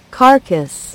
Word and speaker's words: Carcass: Carcass. – É, Carcass, né? Eu falei Carcass. É isso aí, Carcass. Se escreve Carcass, com dois S no final. Carcass, com C e C, tá?